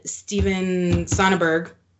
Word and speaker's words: Soderbergh: But we watched the Steven Soderbergh, right? Mm-hmm.